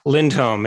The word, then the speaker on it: Lindholm: Lindholm. It's Swedish.